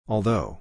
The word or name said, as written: although